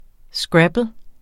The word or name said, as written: scrabble®